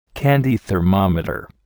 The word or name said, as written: candy thermometer